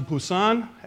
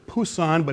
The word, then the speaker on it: Busan: We then went on to Busan. I know it says Busan, but it's actually pronounced Busan, with a guy named Eddie.